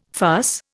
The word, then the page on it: farce: Indian